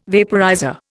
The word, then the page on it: vaporizer: Indian